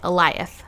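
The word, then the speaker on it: Alioth: That's how you say it. Eliath. I figured it out. Woo-hoo. That's the syllable. – Points. – That's the syllable emphasis.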